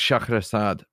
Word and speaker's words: Scheherazade: It's actually pronounced Scheherazade, but whatever.